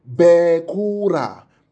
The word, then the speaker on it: bekora: Spell it for us. B-E-K-O-R-A-H. So it's bekora, bekora. Let me now show you something.